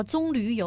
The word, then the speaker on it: 棕榈: 哦，就是这个应该叫棕榈油哈。 – 哦，棕榈是吗？ – 嗯，正常读音就这个，大家听着别扭哈。